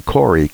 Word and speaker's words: Kory: Normally, we don't sell books here, but it's called The War on Ivermectin, and it's by Dr. You pronounced it Perry, P-I-E-R-R-E, and then Corey, K-O-R-Y.